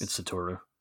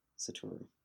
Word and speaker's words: Satoru: And the guy who's showing them around, who's Satoru, I'm not sure if I'm saying that right, because it's a book. Damn books. – It's Satoru. – Satoru.